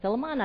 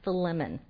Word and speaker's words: Philemon: But for those of you who know better than know that I just did not pronounce that correctly, I did a little research and realized it's actually pronounced Philemon.